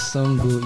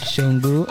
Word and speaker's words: Sango: Okay, one is Songo. You can call it Songo. Another is Shango. I don't know if you're Yoruba. But it's spelled S-A-N-G-O.